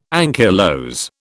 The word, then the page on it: ankylose: British English